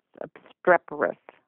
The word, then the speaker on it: obstreperous: And I never write a book thinking to write a sequel, but these characters are being very obstreperous. Did I mispronounce that, didn't I? – No, you pronounced it.